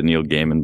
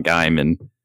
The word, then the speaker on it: Gaiman: And his name is pronounced that way, by the way. I looked it up just to make sure, and that's how he says his name. So it's not Gaiman.